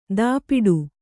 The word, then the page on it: ದಾಪಿಡು: dāpiḍu